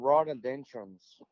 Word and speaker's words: rhododendron: They're the largest bees, and they feed on rhododendrons. I think I said that right. I hope. – Rhododendron. – Yes.